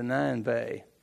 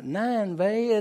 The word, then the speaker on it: Nineveh: And I said, the beginning of the story was God told Jonah to go to Nineveh. And he said, "it's not Nineveh, it's Nineveh." And I said, "Well, open it and phonetically pronounce it for me, please." It's nine bay.